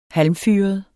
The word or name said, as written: halmfyret